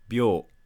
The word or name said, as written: byou